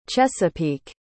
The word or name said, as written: chesapeake